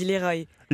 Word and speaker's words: Leroy: Je vous annonce au passage qu'Émilie Mazoyer reçoit Bébé Brune, Songe, Féfé et Le Roi pour un concert exceptionnel en public et en direct du studio Belmar ce soir. On ne dit pas, on ne prononce pas comme ça. – On dit Leroy.